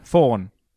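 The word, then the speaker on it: faun: A thorn? – A thorn, thank you. He's a thorn. – Fawn. F-A-W-N. Not a thorn.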